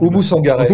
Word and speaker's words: Oumou Sangaré: Je vous propose qu'on écoute le, premier titre, hein, tous vivants, Youssoufa et Amou si je l'ai bien prononcé, j'ai toujours. – Oumou Sangaré. Oumou